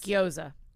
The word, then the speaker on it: gyoza: Gyoza.